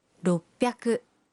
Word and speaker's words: roppyaku: 600 se convierte en roppyaku.